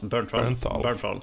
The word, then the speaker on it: Bernthal: Hur fan man uttalar hans? – Bernthal.